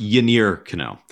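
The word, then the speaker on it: Yennier Cano: So it's actually pronounced Yainer Cano.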